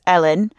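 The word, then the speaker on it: Ellyn: It is Ellen, just like Ellen Bannon, very similar pronunciation, but with the YN.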